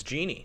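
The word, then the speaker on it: Genie: I think one of my favorite things about her is that we had Jenny Bouchard, and everyone just fucking forgot about Jenny. – You forgot how to pronounce her name. It's Jeannie.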